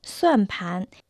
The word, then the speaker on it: 算盘: Правильно, китайские счеты на китайском языке звучат как «суанпан». Первый иероглиф «суан» произносится в четвертом доне, носит значение «считать расчеты». Второй иероглиф «пан» произносится во втором доне.